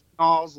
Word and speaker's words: Nalls: It's Nalls.